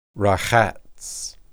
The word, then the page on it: rahats: Hebrew